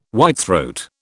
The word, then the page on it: whitethroat: British English